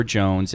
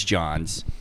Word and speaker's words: J'onzz: J O N Z Z is never Jones. It never will be Jones. There's no reason for it to be Jones. It's Johns.